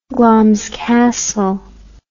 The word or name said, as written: Glamis Castle